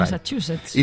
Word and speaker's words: Massachusetts: No sé si lo he dicho bien, pero bueno, por Massachusetts.